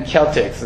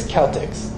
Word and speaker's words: Celtics: Man, I hate them Celtics. You're not even saying it right. It's Celtics.